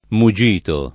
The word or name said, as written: mugito